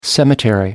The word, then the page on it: cemetery: American English